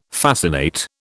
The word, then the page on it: fascinate: British English